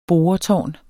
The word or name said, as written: boretårn